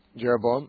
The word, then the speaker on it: Jeroboam: And I wanted to talk to you about the, in my devotional today, it was 1 Kings 13, 31, when Jeroboam, is that how you pronounce it? – Jeroboam, uh-huh.